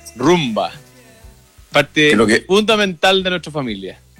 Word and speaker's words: Roomba: Rumba se escribe R-O-O-M-B-A. Rumba.